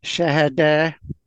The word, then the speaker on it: Shahada: If you were in Egypt, it would be pronounced Shahada.